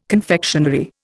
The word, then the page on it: confectionery: Indian